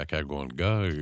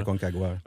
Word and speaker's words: Aconcagua: On a entendu également pour la Aconcagua, c'est comme ça qu'on le prononce en Argentine. – La Aconcagua.